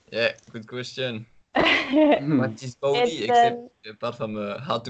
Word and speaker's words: Vaude: So many would call it Vaude or Vaud, because they think it's French, because it's written like with a V-A-U-D-E. So yeah, many people don't know that it's actually a German-based company.